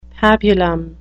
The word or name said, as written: pabulum